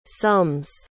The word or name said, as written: sums